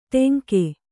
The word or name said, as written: ಟೆಂಕೆ